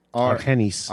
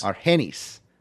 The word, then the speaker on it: Argenis: The final four, Mr. Iguana, Carta Brava Jr., Mysticis Jr., and was it Argenis? – Argenis. – Argenis. I always mess up on the soft G's and J's.